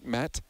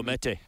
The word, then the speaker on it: Mete: Is it Met? How do you say his last name? – Oh, Mete.